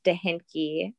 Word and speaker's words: Dehnke: So Danky is German. Dehinke. It's spelled DEHNKE.